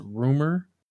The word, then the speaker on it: RMR: His title is RMR, but it's pronounced Rumor.